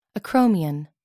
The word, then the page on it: acromion: American English